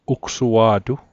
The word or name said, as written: ugsua'tu